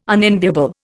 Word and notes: unenviable: Indian

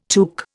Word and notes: tuque: Indian

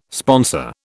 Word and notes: sponsor: British English

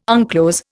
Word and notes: unclose: Indian

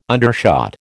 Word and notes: undershot: American English

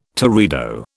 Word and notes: teredo: British English

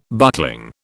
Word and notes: buckling: British English

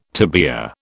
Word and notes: tibia: British English

